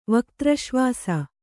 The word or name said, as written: ವಕ್ತ್ರಶ್ವಾಸ